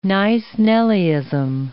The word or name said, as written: nice-nellyism